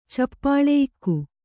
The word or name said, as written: ಚಪ್ಪಳೆಯಿಕ್ಕು